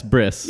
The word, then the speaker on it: bris: Yeah, it's Bris. – Yeah. – Bris. – Okay. – I always say brisk like iced tea.